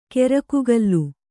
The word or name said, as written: ಕೆರಕುಗಲ್ಲು